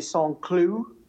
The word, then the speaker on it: Saint-Cloud: The Criterium de Saint-Cloud, Nice pronunciation, my friend.